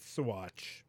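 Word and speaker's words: Saguache: You're pronouncing the letters in English, but these letters are pronounced differently in other languages. Like, isn't G like silent or it makes like a noise? – No, it's Sawatch. – Yeah. – Like, you don't pronounce the G.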